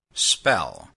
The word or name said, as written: spell